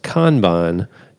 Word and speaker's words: Kanban: So he says, also, it's pronounced Kanban, Japanese technique.